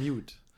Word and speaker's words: Meute: Ich habe mich gefragt, wie werden Meute eigentlich auf Englisch ausgesprochen? – Ja, die sind sich da auch manchmal nicht so einig. Aber eigentlich die meisten sagen Mute.